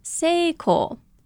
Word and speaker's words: Seiko: Now the third and fourth characters can be the name Satako or Kyoko, but can also be Seiko, pronounced just the same as the famous watchmaking company.